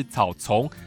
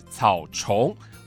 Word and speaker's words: 草蟲: 进入到了暑假，故宫推出了适合亲子共享的草虫捉迷藏特展，邀请大小朋友走进故宫来欣赏草虫展。不是我发音有问题哦，不是草丛，是草虫，毛毛虫的虫。过去我们介绍以花卉植物为主的图画作品，而这一回的草虫展就将主角换成了花草间的昆虫跟小生物。